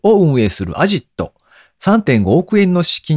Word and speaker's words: AZIT: ラストワンマイル配送プラットフォームクルーエクスプレスを運営する株式会社アジット、 AZIT と書いてアジットというもそうなんですけれども、こちらの会社が資金調達をしたよという発表ですね。